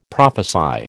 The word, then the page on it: prophesy: American English